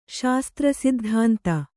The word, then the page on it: ಶಾಸ್ತ್ರಸಿದ್ಧಾಂತ: Kannada